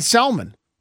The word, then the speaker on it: salmon: Via the YouTube chat, will you please stop pronouncing the L in salmon? No. No, we will not stop doing that.